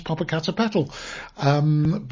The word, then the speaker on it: Popocatépetl: I've got to learn how to pronounce Popocatépetl.